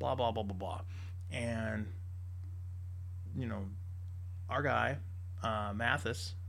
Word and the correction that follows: Mattis: and I might be pronouncing his name wrong, you know, and so if I am, you know, forgive me. Mattis, there we go.